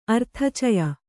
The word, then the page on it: ಅರ್ಥಚಯ: Kannada